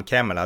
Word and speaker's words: Kamala: och då skriver hon att hennes namn Kamala, det uttalas Kamala, uttalas det.